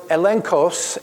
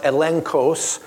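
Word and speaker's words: elenchos: Now let's look at another word, the word evidence, and that's the Greek word elenkos, and I know it sounds like it's a E N G but it's actually spelled that way and pronounced elenkos, which means a proof or a test.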